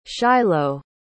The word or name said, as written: shiloh